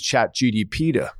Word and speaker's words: ChatGPT: So I remember when somebody in the office came to me and said, look, let's just use ChatGPT. Is it GDP or? – ChatGPT, yeah.